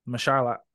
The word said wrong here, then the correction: Mahershala: Mash- Mahershala.